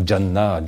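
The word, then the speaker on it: Jannah (جنة): Und von dort aus teilte er sich und wurde zu vier Flüssen, Garten, Gan, aramäisch Ganna, das wird äh, arabisch, also arabisch ausgesprochen, so Jannah, Jannah, das ist ein äh, aramäisches Wort, hebräisches Wort.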